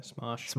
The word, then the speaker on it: Smosh: Smosh.